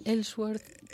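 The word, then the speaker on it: Ellsworth: Bueno, ahora no logro pronunciarlo con exactitud. – Sí, es Elsworth.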